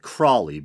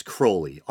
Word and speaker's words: Crowley: A lot of people pronounce it Crowley, but I think it's properly pronounced Crowley.